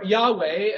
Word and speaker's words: Yahweh: And those four letters in the original Hebrew, Yod-Heh-Vav-Heh, or Yahweh, as we now pronounce it, is the divine name.